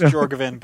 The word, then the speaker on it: Jorgovan: Just Jorgovan, but it's fine.